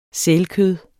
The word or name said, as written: sælkød